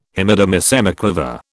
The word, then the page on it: Hemidemisemiquaver: British English